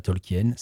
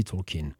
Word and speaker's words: Tolkien: qui est une des nombreuses langues inventées par Tolkien et qui figure dans plusieurs livres du Seigneur des Anneaux. Oui, je suis désolé, je fais mon snob angliciste. Ça ne se dit pas Tolkien, ça se dit Tolkien.